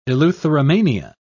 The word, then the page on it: eleutheromania: British English